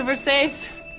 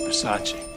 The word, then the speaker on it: Versace: It's a Versace. – It's Versace. – What? – Versace. It's pronounced Versace.